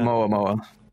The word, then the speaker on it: Oumuamua: Oumuamua.